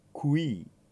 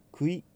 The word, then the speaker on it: qui: まずうを発音します。 ku ku ではなくて k の後ろにうをつけて ku、その後にいに変える qui qui、このように発音されます。クイではないので十分注意してください。